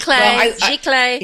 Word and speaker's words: giclée: Giclée.